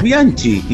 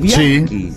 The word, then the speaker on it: Bianchi: Decimos Carlos Bianchi, y es Bianchi.